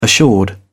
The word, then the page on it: assured: British English